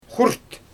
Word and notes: xurt: Xyzyl

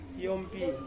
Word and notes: yompi: Ansus

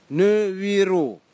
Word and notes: niviro: Kwamera

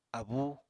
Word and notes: abúu: Apatani

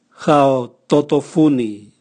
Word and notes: gaototovuni: Raga

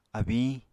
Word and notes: abiñ: Apatani

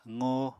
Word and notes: qo: apatani

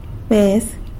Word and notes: bez: sanlucasquiavini

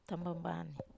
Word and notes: tamambanei: Wamesa

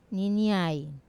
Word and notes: niniai: wamesa

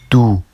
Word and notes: du: Vafsi

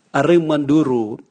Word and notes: arimwaduru: Raga

